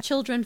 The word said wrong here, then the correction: Chiltern: As in children. – No, it's Chiltern. Chiltern.